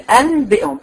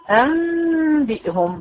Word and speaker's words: أنبئهم: عشان الثقل ده علماء التجويد استبدلوا النون بحرف قريب من الميم، قريب من الباء، عشان يبقى سهل في النطق. فبنقول ايه؟ أنبئهم، بحول النون الى ميم.